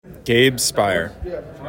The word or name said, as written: Gabe Speier